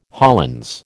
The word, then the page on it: Hollands: American English